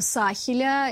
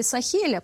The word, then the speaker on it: Сахеля: И это как раз страны Сахеля или Сахеля, по-разному произносят.